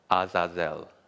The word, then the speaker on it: Azazel: So it's the first syllable is Az, like the Great Wizard of Oz. And the next one is Zah, like Zah. And then the last one is Zel, Azazel. So Azazel is the Hebrew word for goat.